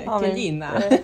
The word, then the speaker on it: Kelina: t'as créé Kéline. – Ouais. Kelina. – vraiment, je, vais, je vais être italienne à chaque fois que je vais le prononcer.